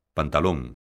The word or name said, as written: pantalón